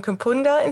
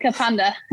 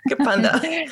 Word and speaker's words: Kapunda: And forgive my pronunciation if this isn't right, but you're from Kapunda in South Australia? – Yeah, so, nearly got it. Kapunda. – Kapunda.